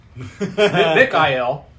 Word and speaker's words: Mikael: Mikael. Mikael should be the pronunciation of Michael.